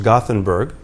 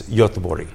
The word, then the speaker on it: Göteborg: Because in America, it's pronounced Gothenburg. In Germany, it's Göteborg. And now here, I think it's Göteborg. Is that correct? – Yeah.